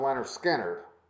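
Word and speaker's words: Lynyrd Skynyrd: Because I think, pronounced Leonard Skinner, may be the better album.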